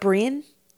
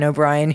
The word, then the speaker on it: O'Brien: It's O-B-R-I-E-N. Is that Brian? – No, it's O'Brien.